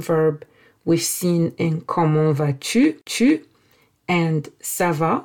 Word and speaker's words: vas-tu: Comment vas-tu? Comment, how, vas-tu, are you? Tu is you. And yes, this va, where the final S is silent, is the same va we've seen in ça va.